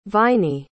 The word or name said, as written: viney